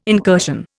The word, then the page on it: incursion: Indian